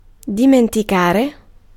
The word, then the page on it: bury: /ˈbɛ.ɹi/; [ˈbɛɹ.i]